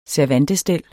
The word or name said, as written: servantestel